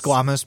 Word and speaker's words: squamous: It's pronounced squamous, but it is a word.